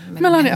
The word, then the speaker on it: Melania: Jag kan inte riktigt uttala hennes namn. – Melania, ja, precis.